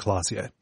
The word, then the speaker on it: Klaasje: So it's Revachol, Oranje, and Klaasje.